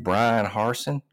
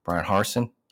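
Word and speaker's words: Bryan Harsin: But if you're going to hire somebody like Brian Harsin, and I said his name right that time, Brian Harsin, that's right, then you better make sure you fucking give him a chance, man.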